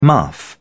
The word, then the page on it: muff: British English